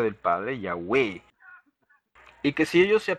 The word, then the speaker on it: Yahweh: Evidencia externa, o más o menos así dice, muestra que se pronunciaba Yahweh.